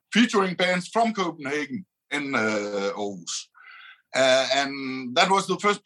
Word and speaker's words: Aarhus: I'm from Aarhus. Aarhus. In the middle of the street, you know, that's how to pronounce it. I heard you were having trouble pronouncing it, but that's our house, Aarhus, you know, so that's where I'm from, and I've lived all my life.